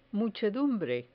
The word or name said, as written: muchedumbre